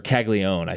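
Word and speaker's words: Caglianone: With Jack Caglianon, or Caglione, I think is the official pronunciation, even though that's not how it's written at all. Not even close.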